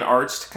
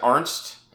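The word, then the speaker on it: Arzt: Hurley and Arst kind of, you know, banter back and forth about his name because Hurley pronounces it Arnst instead of Arst.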